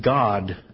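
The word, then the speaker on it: God (גד): In some of the scriptures, it speaks of Gad, which the god Gad, which is spelled Gimel Dalet in Hebrew, is pronounced God in Hebrew.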